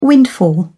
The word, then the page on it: windfall: British English